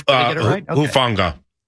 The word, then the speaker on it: Hufanga: Talanoa Hufanga. Did I say that right? Good with pronunciations. – Hufanga. – Hufanga. Yeah. Thank you.